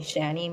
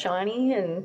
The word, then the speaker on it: Shani: Even my own name, like, people have called me Shani my whole life, and I've always had to say, no, it's actually pronounced Shani.